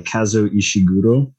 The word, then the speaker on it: Kazuo Ishiguro: We read Never Let Me Go by Kazuo Ishiguro.